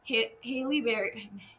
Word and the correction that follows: Halle Berry: Gothika starring Halle Berry.